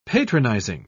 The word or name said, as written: patronizing